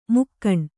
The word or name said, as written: ಮೈಗಣ್